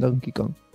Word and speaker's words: Donkey Kong: Me gusta que ya pronuncias bien Donkey Kong.